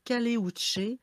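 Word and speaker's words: Caleuche: Oui, en fait, moi, j'ai le Caléuche, où ça s'écrit Caluche, mais en fait, dans le, c'est en Chili, en fait. Donc, c'est le Caléuche.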